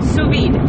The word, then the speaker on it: sous vide: Sous vide. Yes, you got it. You pronounce it right.